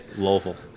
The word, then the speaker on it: Louisville: heading to Louisville for a week. – Louisville. – We won't be here on a Monday. – Louisville. – So, two syllables.